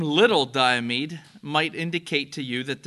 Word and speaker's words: Little Diomede: Little Diomede.